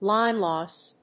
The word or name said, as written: line loss